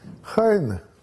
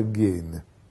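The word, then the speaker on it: Heine: Э, ну, есть такое двустишее в одном из сатирических стихотворений Хайна, который э, у нас традиционно произносится Гейне – он всем своим невежеством обязан был себе.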